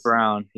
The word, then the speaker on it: Braun: Christian, Christian Braun, or no, that's actually Christian Brown, isn't it? I it's think it's Christian, Brown, yeah, from Kansas. It's Brown, yeah, it's it's Brown for some reason.